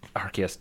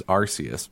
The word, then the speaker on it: Arceus: So the Japanese pronunciation is Arceus, but the English pronunciation is Arceus, and that's because the UK dubbing or whatever, they got it first, or like they had like someone from the UK that was like the director or something like that, yeah.